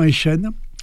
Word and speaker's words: Eyschen: Nul doute que la publication du livre de Christian Eichen, ça s'écrit E-Y-S-C-H-E-N,